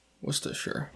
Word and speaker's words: Worcestershire: And my last call is that evidently Worcestershire has no R's pronounced in it. – The Worcester. – Yeah, I guess. – Worcestershire.